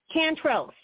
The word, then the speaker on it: Cantrell: Cantrell, C-A-N-T-R-E-L-L.